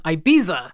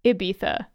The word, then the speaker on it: Ibiza: I usually hear people say Ibiza. – Ibiza. I mean, that's probably a more accurate vowel sound than what I was doing. – Soft I and then a TH for the Z.